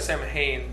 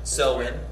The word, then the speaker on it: Samhain: So yeah, it says it looks like Samhain, but it says it's pronounced. Samhain.